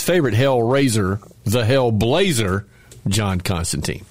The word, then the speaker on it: Constantine: Yeah, that's how you really say his name. John Constantine.